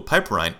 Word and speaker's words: piperine: And piperine, I hope I'm pronouncing that right. It's P-I-P-E-R-I-N-E. Piperine, I believe that's what it is.